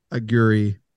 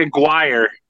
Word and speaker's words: Aguirre: Jalen Brunson is no Mark Aguirre. I don't know if I'm pronouncing that right. – No, it's Mark Aguirre.